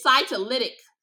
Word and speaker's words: cytolytic: It's called cytolic, if I'm not pronouncing that right. It's, hold on, y'all. I gotta search it up to see how to spell it. Because she gave me literally a pamphlet of what it means. Cytolic vaginosis. If I think I'm pronouncing it wrong, it's cytolytic.